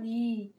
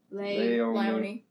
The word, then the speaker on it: Leonie: Because it's spelled L-E-O-N-I-E. – Yeah, but Leonie. – Leonie. – Leonie. Leonie! Her name is pronounced Leonie.